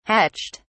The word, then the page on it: hatched: British English